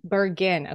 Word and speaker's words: Bergin: I should have asked you, is that how you say your last name, Bergin? – Bergin.